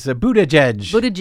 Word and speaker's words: Buttigieg: In related news, South Bend, Indiana Mayor Pete Buttigieg, who is Buttigieg.